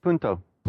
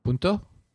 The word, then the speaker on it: Punto: How do you pronounce that? – Punto. – Punto? Punto? – Yeah, okay.